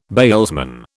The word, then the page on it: bailsman: British English